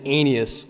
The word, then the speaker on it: Aeneas: I'm always careful when I pronounce that. I use three syllables instead of two. Met a man named Aeneas who had been paralyzed and bedridden for 8 years.